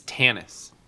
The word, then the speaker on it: Thanos: I thought that was supposed to rhyme with anus. – It's Thanos.